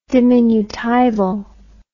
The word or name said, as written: diminutival